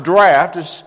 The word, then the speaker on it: draught: and goeth out into the draught, which is a word that we hardly ever use in this context, purging all meats. The worst, and it's, and we often mispronounce it, but it's pronounced draft.